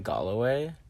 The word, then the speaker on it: Galloway: I feel like in the song, is it pronounced Galloway? – Mm-hmm. Okay.